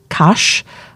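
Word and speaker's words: Cach: See, his name's C-A-C-H. It's hard to say when you're Welsh, but I think he pronounces it Cash.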